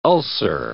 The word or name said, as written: ulcer